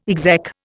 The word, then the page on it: exec: Indian